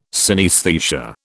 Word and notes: synaesthesia: British English

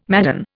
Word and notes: madden: Indian

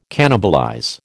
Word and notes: cannibalize: American English

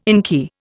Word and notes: inky: Indian

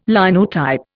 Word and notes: Linotype: Indian

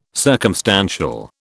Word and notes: circumstantial: British English

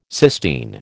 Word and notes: cysteine: American English

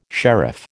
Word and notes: sheriff: American English

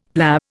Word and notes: PLA: Indian

